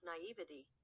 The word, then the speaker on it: naivety: How do you say that word? Naivety. Naivete. Naivete. Hey, Google. How do you pronounce naivety? – That's pronounced naivety. – Oh, well, I guess it depends who you ask.